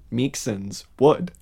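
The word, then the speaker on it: Meiksins Wood: I'm going to say her name correctly this week. Ellen Meeksens Wood. – Excellent.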